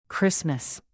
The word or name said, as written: christmas